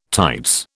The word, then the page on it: tights: British English